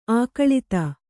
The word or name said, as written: ಅಗಲಿತ್ತು